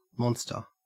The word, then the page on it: monster: Australian English